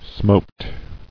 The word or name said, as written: Smote